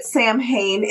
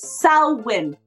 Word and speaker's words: Samhain: I know it's spelled Samhain. Please stop pronouncing it Samhain. It's Samhain.